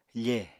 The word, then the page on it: fe: apatani